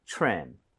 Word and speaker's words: trim: TRE 붙어 있어요. TRE 붙어 있고 그다음에 모음으로 연결이 되면 크리스마스 트리처럼 치읓 비슷해집니다. – 그쵸. – 그래서 이 모양을 모으고 발음해 주세요. 가보겠습니다. Trim, trim.